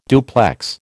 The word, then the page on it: duplex: American English